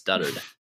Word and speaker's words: dotard: Dotard is how it's spelled, but you can't pronounce it that way. So it's duttered.